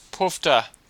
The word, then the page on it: poofter: Australian English